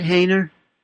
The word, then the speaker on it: Haner: Is that how you say your last name, you said, Hainer? – Yeah. – H-A, how do you spell it? – H-A-N-E-R.